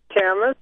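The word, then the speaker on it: Camas: Todd's calling from Camus, Washington. Am I getting that correct? – It's actually Camus, but Camus. Close enough. – I saw it spelled like the French author.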